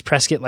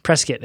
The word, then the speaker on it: Prescott: It's Prescott like biscuit. There we are. – Prescott. – If anybody ever wonders, just remember biscuit. That works well.